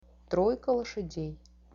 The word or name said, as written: troika loshadei